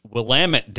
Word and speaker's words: Willamette: It's Willamette, damn it.